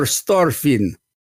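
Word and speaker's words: Starffin: He is no Victor Starfin. Actually, he was a pretty good pitcher, though. – I believe it's pronounced Victor Starfin.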